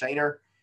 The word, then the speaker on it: Schoener: So reach out to me on Facebook, Justin, last name Schoener, pronounced Schoener.